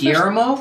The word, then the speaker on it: Guillermo: You must roll Guillermo. – Your tongue. – Listen to you idiots do that all night. – Because you can't roll your tongue. – I can't. – You roll your R's.